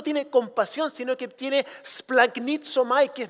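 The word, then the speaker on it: splanknitzomai (σπλαγχνίζομαι): Esa palabra compasión en el griego se pronuncia splanknitzomai.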